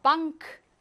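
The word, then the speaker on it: Bank: 이렇게 발음하는데, 엔카는요, 방크, 당큰, 쉔크, 크가 살아나요. 이거를 훈거, 훈거 하시면 안 돼요. 훈거입니다. 하지만 뒤에는 카가 살아나서 방크입니다.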